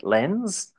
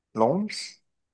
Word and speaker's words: Lens: He went off to, how do I pronounce this in French? Lens. Or if I were to just pronounce it phonetically, Lens. But Lens, French.